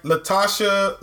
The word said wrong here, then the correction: Lashana Lynch: Lashana Lynch. I'm sorry. I butchered that.